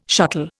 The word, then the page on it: shuttle: Indian